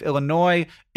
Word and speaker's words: Illinois: That's great, but she keeps calling my home state of Illinois, like she pronounces the S at the end.